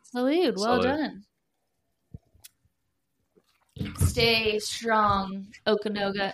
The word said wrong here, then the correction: Okanagan: Is that how it's pronounced? – Okanagan. – Okanagan sounds very like, let me go get my toboggan.